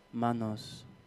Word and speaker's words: Manos: From it's pronounced 300 Manos.